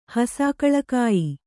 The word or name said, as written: ಹಸಾಕಳಕಾಯಿ